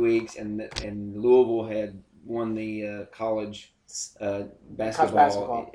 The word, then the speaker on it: Louisville: Or Louisville.